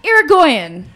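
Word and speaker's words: Yrigoyen: Irgoyen. – Yeah. – You're going. – Like, you're going to the bathroom.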